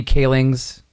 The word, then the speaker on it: Kaling's: They released a trailer for the, and I'm probably going to mispronounce this, Mindy Kaling's. – No, you got it. – You got okay. it. Got it in one.